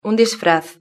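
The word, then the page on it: un disfraz: Spanish (Spain)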